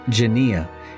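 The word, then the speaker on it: genia (γενεά): The word generation in the Greek, according to the Strong's Concordance, is pronounced genia, and it means an age, the period, or the person's age, generation, nation, time.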